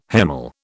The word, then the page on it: hemal: British English